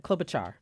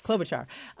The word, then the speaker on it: Klobuchar: Klobuchar. – Yes. – Klobuchar.